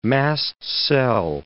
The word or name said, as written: mast cell